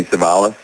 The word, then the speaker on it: Cevallos: Um, and uh, on YouTube, I'm just Jamie Cevallos, uh, spelled with a C, the last name. Um, if you put in the swing mechanic, uh, you'll you'll get my website. Um, uh, Twitter, I'm Jamie Cevallos, and uh, Instagram, the swing mechanic.